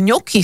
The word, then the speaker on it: gnocchi: No tak som sa do toho trošku zažrala a pozrela som sa, čo mi radí AI, tak vraj ňoki sa to má vysloviť, aj keď sa to píše gnocchi.